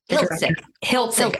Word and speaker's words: Hiltzik: Hiltzik.